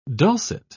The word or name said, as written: dulcet